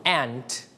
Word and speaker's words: aunt: Aunt. – Aunt. – Aunt. Or we can pronounce this word aunt. เราสามารถออกเสียงได้สองแบบคือ aunt และ aunt นั่นเองนะครับ So this word can pronounce in two ways. Okay, you can pronounce aunt or aunt. It's okay. If you pronounce aunt. That means you use American style. But if you pronounce aunt, you use the British style.